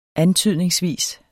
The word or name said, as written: antydningsvis